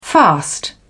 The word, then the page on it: fast: British English